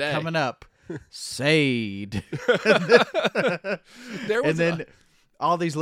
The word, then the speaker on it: Sade: So, of course, you know it's Sade.